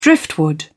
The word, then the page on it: driftwood: British English